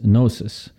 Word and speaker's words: Gnosis: Another related philosophy, which is very important, is Gnosis, which is spelled with a G, like Gnosis.